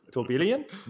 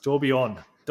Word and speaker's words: tourbillon: Tourbillon? No, it's tourbillon. Tourbillon.